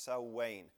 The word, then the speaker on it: Samhain: We go back to somebody named Samhain, and it's spelled, it looks like Samhain, maybe on your paper. It looks like that. It's pronounced Samhain. So many,